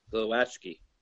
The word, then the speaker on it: Glowacki: I'm sorry, how do we say it again? Glowacki? – Glowacki.